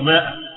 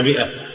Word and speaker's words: مئة: أنا أشوف كثير من الشباب لما يشوفها يقرأها يقول ماء، لا هذا عيب، الألف ديات لا تنطق، اسمها مئة.